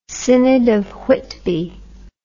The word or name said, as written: Synod of Whitby